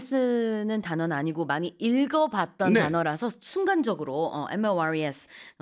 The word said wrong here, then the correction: mores: 이렇게 발음을 했었는데 사전적으로 이제 발음 기호를 찾아보시면은 mores okay. – 이렇게 나와 있긴 합니다.